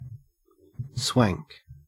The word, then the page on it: swank: Australian English